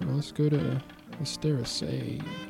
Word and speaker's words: Asteraceae: I don't know how to say the AE thing. – Asteraceae, I think, yeah. – Okay. – Like an AY type of sound.